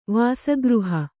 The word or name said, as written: ವಾಸಗೃಹ